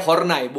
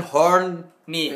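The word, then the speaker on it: Horney: Jadi bacanya Hornai, bukan Horn-ni.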